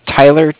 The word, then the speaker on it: Tyler: My name is Tyler, T-Y-L-E-R. – Mm-hmm. – And I'm calling with Cardiff. It's spelled C-A-R-D-I-F-F, as in Frank.